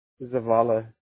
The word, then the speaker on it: Zavala: How do you pronounce that, if you don't mind asking, man? Zayla? – Zavala.